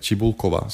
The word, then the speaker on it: Cibulkova: Samantha Stosher är liksom åtton, eller um, Eh, vi har Dominika Cibulkova, eh, Cibulkova som ska uttalas.